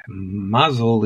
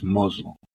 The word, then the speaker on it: Mazel: Mazel is Mozel.